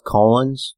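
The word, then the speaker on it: Colón: It's pronounced Colons.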